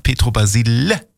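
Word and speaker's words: Pietro Basile: Und da habe ich extra, ich wusste auch nicht, wie hat er nun ausgesprochen? Ja, ich habe bei Giovanni Zarella aufgepasst, aber auch nicht genau hingehört. Aber man hat mir gesagt, Petro Basile.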